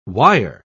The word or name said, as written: wire